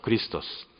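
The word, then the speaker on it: クリ スト ス (Χριστός): ヘブル語ではメシアッハというふうに発音しますけれども、ギリシャ語ではクリストスと言います。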